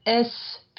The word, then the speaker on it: est: So, for example, in voice 2 of verse 2, l'herbe est plus fine. The grass is finer, okay? That verb être, there you need to pronounce the S. Because otherwise you're going to mix it up with a, meaning and.